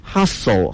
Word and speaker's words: hustle: 是 hustle 和 bustle 那个 t 是不发音的。